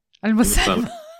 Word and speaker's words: Almasalmeh: I'm your host, and today we start a new series, Episode 1 of 5, with Hassan Almasalmeh. Did I say it right? Almasalmeh. – Close enough.